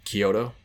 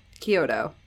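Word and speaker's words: Chiodo: Kyoto. – Kyoto.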